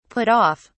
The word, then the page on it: put off: British English